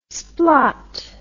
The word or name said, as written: splot